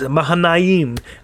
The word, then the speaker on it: Mahanaim: So he called the name of that place Mahanaim, is how it's pronounced, as Mahanaim, the emphasis on the last syllable.